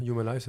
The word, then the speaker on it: Humanize: Je ne sais pas si on prononce en anglais en fait. Mais... Humanize, c'est très bien.